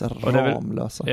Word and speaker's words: Ramlösa: Det uttalas Ramlösa.